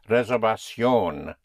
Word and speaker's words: reservación: The V is pronounced with your lips, with like a soft B. Reservación.